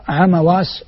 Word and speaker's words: عمواس: وكذلك تنطق عمواس بفتح الميم